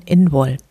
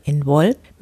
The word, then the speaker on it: Imbolc: Der keltische Name für Lichtmess wird Imbolc geschrieben und wird irisch ausgesprochen Inwol.